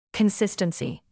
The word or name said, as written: consistency